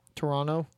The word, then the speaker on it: Toronto: You can tell she's not that, internationally inclined, pronouncing Toronto. Yeah, you don't do the last T. – Toronto? – Yeah.